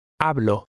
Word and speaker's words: Hablo: The H is silent. Try to read number 15. – Hablo. – An initial H is always silent.